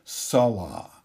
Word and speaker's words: Salah: The first one is called Salah, and it is spelled S-A-L-A-H, Salah, and it represents prosperity.